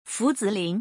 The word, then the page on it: 伏子林: Fu Zilin